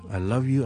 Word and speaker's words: love: Valentine's Day， OK， love 咧有个 VM 喺后面嘅， so loving，譬如我话 I love you 咧，大家听一次，I love you， I love you， I love you， I love you， it's a V sound， the voicing continues， I love you， I love you， I love you， I love you，就唔好读成 I love you 咁啦， it's I love you。